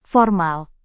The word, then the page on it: formal: indonesian